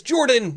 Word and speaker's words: Jordan: My name is Jordan, with a silent PH in the middle, and I am joined always by my wonderful co-host, Sir Lucian, over at Sir Lucian Gaming.